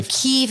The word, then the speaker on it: Kyiv: And so the background on that is that Kiev is the Russian pronunciation. – Oh. – And the Ukrainians are saying we would really prefer if you pronounced our capital the way we pronounce it.